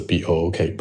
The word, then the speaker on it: book: 再比如说书这个单词 b o o k book，其实这个中间的小 o 是一个非常小的小口 book，但很多人愿意把嘴撅起来去说 book，那这个时候其实在考官听来呢。